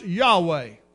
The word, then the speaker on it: Yahweh: Jehovah is a transliteration of a Hebrew word that we think is pronounced Yahweh, but we're not exactly sure, but we think that's the way you say it.